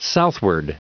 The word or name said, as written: southward